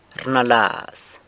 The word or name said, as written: rnalaz